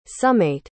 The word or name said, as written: summate